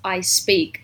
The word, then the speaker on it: IC3PEAK: That reminds me of, there's this one Russian group or a Russian singer called Ice Peak, spelled I-C-3-P-E-A-K. But it's pronounced I speak, like to speak.